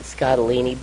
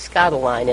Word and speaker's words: Scottoline: At least Eloquence Voices pronounce it Scottolini, but it is Scottoline, and I've read a few of her books, and she is good.